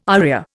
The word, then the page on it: aria: Indian